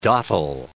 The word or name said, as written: dottle